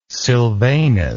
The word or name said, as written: silvanus